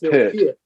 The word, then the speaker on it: Peart: it's like some people call him Neil Peart, when in actual fact it's Neil Peart. Sorry, Neil Peart, okay. But it's Neil Peart.